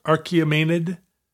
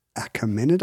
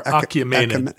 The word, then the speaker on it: Achaemenid: How is that pronounced? Archaemenid? – Achaemenid. I've never had to say it. – Oh, it's it doesn't have an R. Achaemenid. Achaemenid.